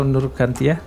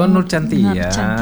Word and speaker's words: Nur Shantia: Itu bacanya apa tuh? Nur Nur Shantia atau Nur Kantia? – Nur Shantia. – Oh, Nur Shantia. Sesuai dengan ini ya tulisannya.